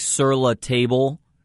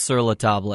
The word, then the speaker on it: Sur La Table: It looks like Sur La Table, but it's pronounced Sur La Table.